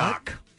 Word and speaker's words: knock: Yeah, the K is silent. – Well, he thinks it might be like, you know what? Knock.